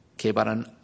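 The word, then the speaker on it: Cephas: 게바는 아람익식으로 발음하는 것이고 페트루스, 베드로는 히랍식으로 발음한 것입니다.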